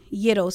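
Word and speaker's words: Eadoh: So you can follow me on Instagram at Ruby Yiddo. So that's spelled RUBYEADOH. A lot of people asked about that, what that means. So Ruby Yiddo. Yiddo is my middle name, and that's actually my grandmother's name.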